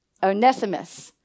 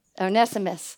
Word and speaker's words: Onesimus: How do you say the servant's name? Onesimus. Onesimus? Onesimus.